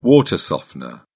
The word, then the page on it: water softener: British English